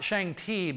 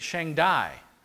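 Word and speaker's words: Shang-Dai (上帝): And they actually pronounce that not Shang-Ti, but Shang-Dai. Well, guess what? That is so close to the Hebrew pronunciation El Shaddai.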